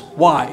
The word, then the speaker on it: wai: And the way, that's their greeting, I think written W-A-I, but pronounced as Y. It's the Y, sorry, not the way, it's the Y.